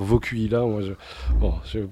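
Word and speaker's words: Vokuhila: Alors Vocuila, en fait, ça se prononce Vocuila, et c'est un mot allemand qui veut dire nuque longue.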